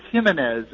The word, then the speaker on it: Jimenez: And I was told, it's Jimenez. And I said, well, then how do you pronounce his first name? And the smart aleck said, well, we call him Ho.